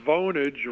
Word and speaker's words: Vonage: Is it Vonage or Vonage? – Asking the important question. – It's a very serious question here because I think it's Vonage rhymes with bonage.